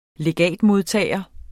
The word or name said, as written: legatmodtager